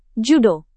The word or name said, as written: judo